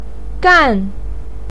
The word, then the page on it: gan: 4